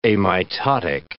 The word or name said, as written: amitotic